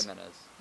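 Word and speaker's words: Jiménez: Tell me if I'm pronouncing his name wrong, Eloy Jimenez. – Jimenez. – That's right.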